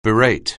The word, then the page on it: berate: anglais